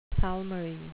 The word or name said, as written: palmary